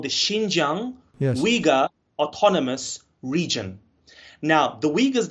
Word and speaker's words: Uyghur: So in my language, you would say Uyghur. Uyghur. But in the Western English, they normally Uyghur. Say Uyghur.